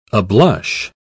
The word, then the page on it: ablush: British English